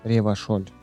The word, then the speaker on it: Ревашоль: Ревашоль без Н. – А я не сказал Н. Ты ничего не докажешь. – Ревашоль, ранее классе Учись говорить правильно.